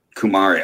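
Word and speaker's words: Kumare: It's K-U-M-A-R-E. – Kumari.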